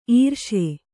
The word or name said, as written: ಇರಿಸು